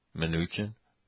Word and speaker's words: Mnuchin: The laughter was Steve, as we would pronounce it in, the Secretary of Commerce, or the Treasury, Mnuchin, as we would say it.